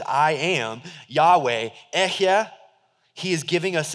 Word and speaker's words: Yahweh: It's four consonants, but we pronounce it Yahweh.